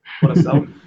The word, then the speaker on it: Curaçao: Ils ont un groupe relativement compliqué, parce qu'il y a le Mexique et la Jamaïque devant, mais après, il y a Curaçao, c'est comme ça qu'on prononce ça ? – Curaçao.